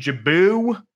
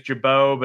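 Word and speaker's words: Gibaut: Ian Jabou, I know his name is Jabot, but it always makes me think of the Fish song Got a Jabou, so I just say it wrong.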